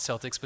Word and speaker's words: Celtic: It looks like Celtic, like the Boston Celtics, but it's pronounced Celtic.